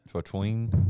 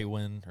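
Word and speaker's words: Trautwein: Let me see it. Twatween? No. They said it last Traywin night. Traywin or something. Traywin? Like that.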